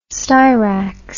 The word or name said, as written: styrax